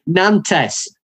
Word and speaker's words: Nantes: Nantes. You don't understand pronunciation. I'll do it for you. Nantes. Nantes.